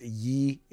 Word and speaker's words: Ye: For those of you who do not know who Kanye, or I think it's pronounced Ye, is, that's his new name.